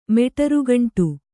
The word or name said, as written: ಮೆಟರುಗಂಟು